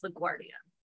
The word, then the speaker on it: LaGuardia: And I will say that correction of pronunciation is for Mike from Zone 18. It's LaGuardia.